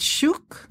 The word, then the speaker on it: shuk: Souk is the Arabic pronunciation, and shuk is a Hebrew pronunciation. – Beautiful. – Very similar. – So shuk, S-H-U-K, colon, From Market to Table, The Heart of Israeli Home Cooking.